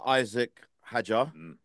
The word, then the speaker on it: Isaac Hadjar: Right, quick shout out to Isaac Hadjar. I'm pronouncing his name right. I'm butchering everyone's names lately, but No, spot on.